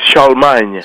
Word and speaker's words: Charlemagne: Well, it's Charlemagne. – Charlemagne. – In French. Yes.